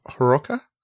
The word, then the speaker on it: Hiroka: I believe the H is sound, so Hiroka and Kahota.